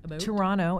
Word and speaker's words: Toronto: Toronto, as we say. We don't pronounce that T.